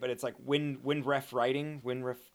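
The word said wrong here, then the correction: Nicolas Winding Refn: Oh no, like Nicholas Winding Rafe.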